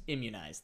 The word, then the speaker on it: immunize: Immunize. That's not it. Give them their shots. – Immunize. – There you go. – I think you just put the syllable in the wrong spot.